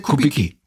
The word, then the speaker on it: Kubicki: Der Kubicki. Der Kubicki, ne? Ja. Der Herr Kubicki von der FDP ist ja auch im Vorstand irgendwie, ich glaube auch Vizevorsitzender der FDP. Der Herr Kubicki war wohl ein persönlicher Freund von ihm.